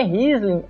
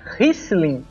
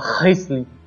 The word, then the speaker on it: Riesling: Mas uma informação mais útil, que a pronúncia correta não é Riesling, é Riesling, como se tivesse com C. Prepara o pigarro como se fosse fazer uma coisa nojenta e fala Riesling.